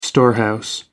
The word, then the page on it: storehouse: American English